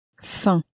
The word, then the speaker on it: faim: But the M on the end of faim was not pronounced, at least not clearly. Perhaps very nasally it exists, but it's not pronounced clearly.